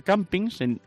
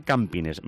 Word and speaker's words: campines: Yo decía campings, como en inglés, pero se dice campines.